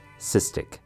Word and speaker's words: cystic: For the first word, we have cystic, two syllables. The first syllable, cis, sounds more like it's spelt S I S with a short I sound. Cis. The second syllable, tick, has a short I sound as well. Tick, cystic. Next up, we have fibrosis.